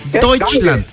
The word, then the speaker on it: Deutschland: Vaya, se dice Deutschland. A ver, Deutschland, repeat after me. Repeat. Deutschland. Ah. – Deutschland. – Eso, qué bárbaro. ¿Sabes qué significa sí. Deutschland? – Alemania, perfecto, amigo.